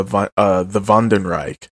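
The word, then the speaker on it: Wandenreich: Captain Kurutsuchi then reveals to us that not only are the Vandenreich, spelled with a W, because little German lesson, in German, W's are pronounced like V's, and V's are pronounced like F's.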